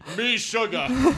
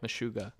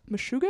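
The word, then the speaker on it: Meshuggah: Me, sugar. – How would you pronounce that? – Sugar. – What? – Meshuga. – Meshuga? Yeah.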